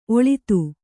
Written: ಒಳ್ಳಿತು

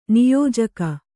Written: ನಿಯೋಜಕ

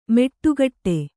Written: ಮೆಟ್ಟುಗಟ್ಟೆ

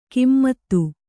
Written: ಕಿಮ್ಮತ್ತು

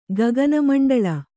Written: ಗಗನಮಂಡಳ

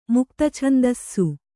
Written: ಮುಕ್ತಛಂದಸ್ಸು

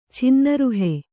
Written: ಛಿನ್ನರುಹೆ

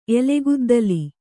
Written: ಎಲೆಗುದ್ದಲಿ

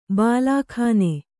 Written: ಬಾಲ್ಕನಿ

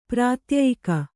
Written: ಪ್ರಾತ್ಯಯಿಕ

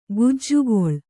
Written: ಕಜ್ಜಗಲಿ

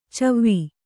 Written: ಛವಿ

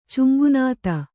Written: ಚುಂಗುನಾತ

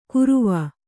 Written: ಕುರುವ

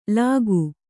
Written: ಲಗೂ

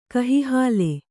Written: ಕಹಿಹಾಲೆ

